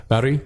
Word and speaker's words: Maori: Um, the thing that I when I was in New Zealand, I met this really awesome guy, and he was a Maori, Maori, I think I'm hopefully Maori? I'm saying it. Maori, yeah. he was telling me how to pronounce it, and that's how he said it.